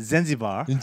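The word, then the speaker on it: Zanzibar: 그 이, 잔지바는 정확한 발음을 이제 네. 정확한 발음이라기보다는 미국식 네. 발음은 젠지바.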